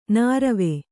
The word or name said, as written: ನೀರ್ವೂ